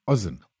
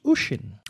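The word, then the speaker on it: Oisín: O-I-S-I-N. – O-I-S-I-O-N. Ozzin. – Almost. – Osama bin Laden. – No, I don't think the Irish name their children that. It is pronounced Ushin.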